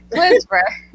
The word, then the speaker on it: Clisver: Clisver.